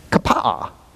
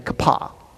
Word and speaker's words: Kapa'a: You are supposed to pronounce both A's in this town. So the correct pronunciation is Kapa'a. But other than the locals, I'm going to say I don't think most people pronounce it that way. I don't pronounce it that way. I just say Kapah.